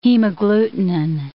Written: hemagglutinin

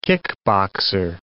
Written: kickboxer

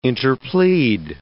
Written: interplead

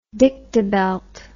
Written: dictabelt

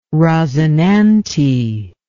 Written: rosinante

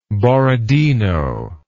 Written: Borodino

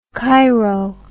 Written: chiro

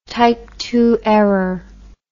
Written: type II error

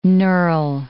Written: knurl

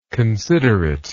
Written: considerateness

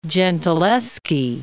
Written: Gentileschi

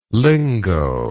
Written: lingoe

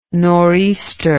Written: nor'easter

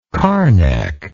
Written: carnacian

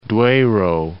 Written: Duero

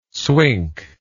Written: swink

